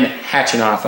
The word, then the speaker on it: Khachanov: And there were some interesting stats when you compared the 2017 run of Jack Sock versus the 2018 run of Karin Khachanov.